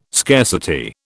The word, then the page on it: scarcity: British English